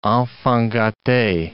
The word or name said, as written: enfant gate